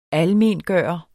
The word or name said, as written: ALMENGJORT